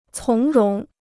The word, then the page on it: 从容: cóng róng